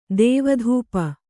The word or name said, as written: ದೇವಧೂಪ